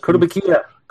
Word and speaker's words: Kotobukiya: Kotobukiya.